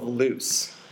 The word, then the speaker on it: Loos: Loo, I think maybe is how it's pronounced. – Probably. – Not like the British bathroom, but like the French word for the place that it was.